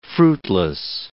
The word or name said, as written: fruitless